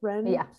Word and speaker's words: Renfrew: Renfrew.